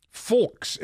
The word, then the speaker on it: Fulks: Uh, and Fulks is his last name. Needs to be spelled and pronounced carefully. F U L K S.